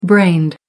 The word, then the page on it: brained: American English